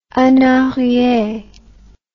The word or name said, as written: en arriere